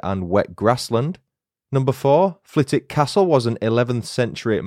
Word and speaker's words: Flitwick: Number one, Flitwick has a silent W in its name. Whilst writing the script for this episode, I was convinced that the town's name was pronounced Flitwick, as in Professor Flitwick, but alas, I was wrong. It's pronounced Flitwick, despite being spelt F-L-I-T-W-I-C-K.